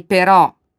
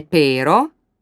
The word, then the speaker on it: però: E però, che non è pero, ha l'accento sulla O.